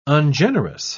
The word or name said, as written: ungenerous